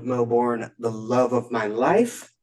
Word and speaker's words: Melbourne: Melbourne, Australia.